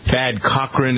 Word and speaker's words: Cochran: Thad Cochran, is that how you pronounce his name? – Yeah.